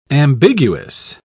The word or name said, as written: ambiguous